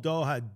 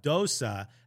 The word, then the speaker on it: Doha: Like, he says, from the moment that Stone called Doha Dosa